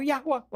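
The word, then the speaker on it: يهوه: يعني اليهودي كان يتحاشى أن ينطق كلمة يهوى، يكتبونها يهوى وينطقونها أدوناي حتى لا يتهموا بالتجديف، لأن في الوصايا العشرة بيقول لا تنطق باسم الرب إلهك باطلا.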